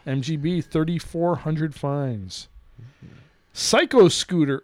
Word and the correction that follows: Psycho Scouter: or sorry, I mispronounced that. Psycho Scouter.